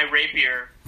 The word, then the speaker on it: rapier: I'm gonna get out my rapier. – And rape him. – There's no character rapier. It's how you pronounce that sword, right? – Yeah. – R-A-P-I-E-R. Rapier.